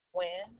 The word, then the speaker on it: Wynn: L-A-S-H-A-Y-E. – And your last name? – Wynn, W-Y-N-N.